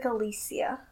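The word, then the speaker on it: Alessia: By Alexa Cara. And that's not how you say her name. – Okay, I'm sorry. What is it? – It's like Alicia.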